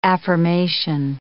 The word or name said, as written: affirmation